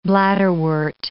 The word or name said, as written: bladderwort